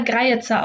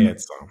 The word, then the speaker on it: Greyerzer: Es gibt Grier oder Greizer ausgesprochen. – Greizer.